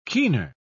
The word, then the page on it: keener: British English